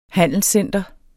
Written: handelscenter

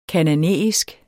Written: kanaanæisk

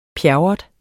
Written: pjerrot

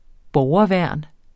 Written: borgerværn

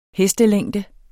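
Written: hestelængde

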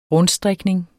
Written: rundstrikning

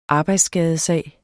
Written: arbejdsskadesag

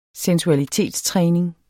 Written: sensualitetstræning

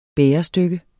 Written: bærestykke

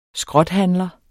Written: skrothandler

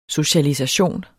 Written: socialisation

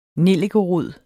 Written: nellikerod

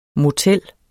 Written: MOTELLERNES

